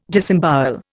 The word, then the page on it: disembowel: Indian